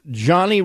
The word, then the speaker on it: Jony: Even though it's spelled J-O-N-Y, it's Johnny Rozon.